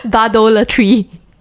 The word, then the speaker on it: bardolatry: Bardolatory. – No, I'm not saying it right. Bardolatry. – Okay. Bardolatry. – Yes, okay. Like bard and idolatry.